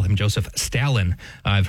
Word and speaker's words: Stalin: Common to use the A that's just more of a typical A rather than R sound. So the Stalin is the way that I grew up pronouncing it.